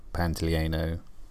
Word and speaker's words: Pantoliano: Um, John Pantalino. Hi there. Um, you might have just noticed that I said that guy's name wrong. His actual name is Joe Pantoliano.